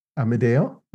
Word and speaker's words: Amedeo: Amedeo? Is that how to say it? Perfect. You said it. Perfect.